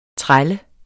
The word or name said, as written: træl